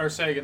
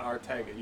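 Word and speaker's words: Arcega-Whiteside: Well, it's you just don't say it properly. Right. Ar- Arcega, not Artega. You gotta say Arcega.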